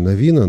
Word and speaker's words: Навина: Я произношу обычно Навина, но произносит по-разному. Я по молодости спорил по поводу этих вещей, потом понял, что есть куда более важные материи, о которых нужно спорить, но уж точно не о том, как произносить Навин или Навина, хотя стою при своем мнении.